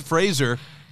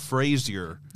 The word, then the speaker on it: Fraser: So, Brendan Fraser. – We love our king. – King Brendan. Brendan Fraser. – And it's Fraser, not Frazier.